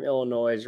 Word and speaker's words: Illinois: I'm actually about 12 feet away from Illinois right now. Don't pronounce the S.